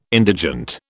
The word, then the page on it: indigent: British English